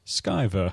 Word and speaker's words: Sciver: There was actually a forum with the artist, Ethan Van Sciver, and some people said it's Sciver. No, it's definitely pronounced that. And then he logs into the forum and goes, Sciver. That was his only comment.